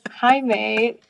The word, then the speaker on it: Jaime: And he introduced himself as Jaime using the Spanish pronunciation.